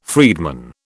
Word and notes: freedman: British English

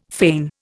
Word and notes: feign: Indian

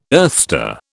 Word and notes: earthstar: British English

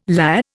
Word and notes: lah: Indian

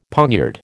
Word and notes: poniard: American English

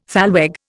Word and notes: thalweg: Indian

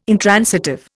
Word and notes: intransitive: Indian

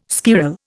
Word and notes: squirrel: Indian

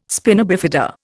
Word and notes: spina bifida: Indian

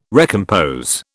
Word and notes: recompose: British English

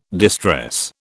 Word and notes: distress: British English

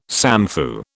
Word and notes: samfu: British English

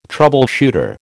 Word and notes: troubleshooter: American English

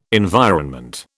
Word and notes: environment: British English

